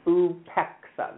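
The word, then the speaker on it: upeksa: and it is UPEXA, that's how it's pronounced.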